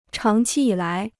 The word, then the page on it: 长期以来: cháng qī yǐ lái